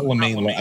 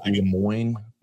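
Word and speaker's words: Le Moyne: Not LeMay. I guess LeMoyne, but.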